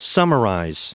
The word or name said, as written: summarize